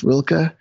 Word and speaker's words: Rilke: And I think it's pronounced Rilke, R-I-L-K-E.